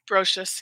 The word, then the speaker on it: Brotious: Are you saying Brocious? – Brocious, yeah. – What is, like, spell that? I love it. – B-R-O-T-I-O-U-S.